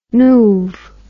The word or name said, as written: noov